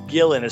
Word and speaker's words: Guillen: Yeah, it's I believe it's Gillen. It's spelled G-U-I-L-L-E-N.